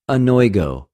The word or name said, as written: ἀνοιγω